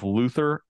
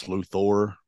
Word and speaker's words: Luthor: I thought was interesting about the movie is they pronounce it both Luther and Luthor, both of them, like Yeah. – interchangeably, is kind of weird. – I've always struggled with that myself. Like, I know it's Luthor, but I've called him Luther before, but like probably my younger years.